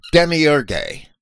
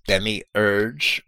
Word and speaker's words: Demiurge: Later Gnostics, in the centuries after Irenaeus, actually developed a view of the Demiurge, and some people might pronounce that as Demiurge or Demiurge, but that E, if you look at the Greek, should not be silent.